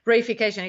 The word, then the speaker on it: reification: Okay, so the next one, I'd never heard of this word ever before, and I'm probably going to say it wrong. Reification? – No, I think you said it right. – Oh, great. – I mean, for English. – I was paying attention. – Reification, it comes from Latin res, which means thing or object.